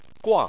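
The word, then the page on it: guang: tone 4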